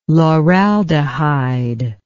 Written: lauraldehyde

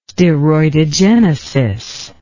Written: steroidogenesis

